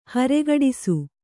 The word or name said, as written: ಹರೆಗಡಿಸು